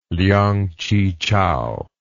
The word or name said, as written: liang chi chao